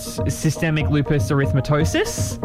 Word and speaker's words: systemic lupus erythematosus: She then was diagnosed with functional neurological disorder and systemic lupus erythematosus. – That's, wow, well done. That's a great pronunciation.